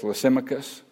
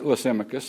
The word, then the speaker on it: Lysimachus: The first general, and I'm not sure the pronunciation of this fellow, but I'm going to spell his name, L-Y-S-I-M-A-C-H-U-S, Lysimachus. I've heard it said like that. Lysimachus sounds good to me.